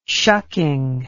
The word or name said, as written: shucking